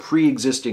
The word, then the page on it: preexisting: American English